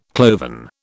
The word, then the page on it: cloven: British English